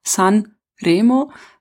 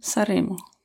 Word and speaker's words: Sanremo: Also nicht Sanremo, sondern Saremo. – Und das ist wahrscheinlich so ein örtlicher ligurischer Dialekt. – Nee, das sagen ja alle so. Das ist einfach die italienische Aussprache. Ich glaube, weil wenn so N auf R trifft, dann ist das tendenziell schwierig und wird halt so ein bisschen verschliffen und verschluckt.